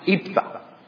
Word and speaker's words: Jephthah: It looks like Jephthah, but it's actually pronounced Iphthah, who was a skilled warrior.